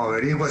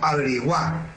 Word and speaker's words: averigua: no la pronuncia como averigua, sino averigua. Ah, Con tilde en la A. Averigua.